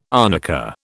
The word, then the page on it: arnica: British English